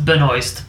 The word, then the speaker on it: Benoist: Yes, it's pronounced Benoist, but it is French.